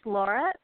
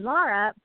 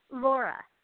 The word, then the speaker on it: Laura: My name is Laura, pronounced slightly different than Laura, but spelled the same.